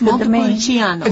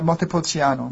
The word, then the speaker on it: Montepulciano: So it's Montepulciano. – It's very close. Yes. I would say Montepulciano.